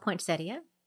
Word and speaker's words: poinsettia: Did you know, Leslie, that it's actually called poinsettia? – No, I did not know that. – That's okay. – So I've been saying it wrong my entire life.